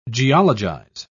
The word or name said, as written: geologize